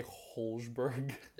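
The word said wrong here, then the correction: Højbjerg: Is that not how you say it? How do you say it? Hoiberg.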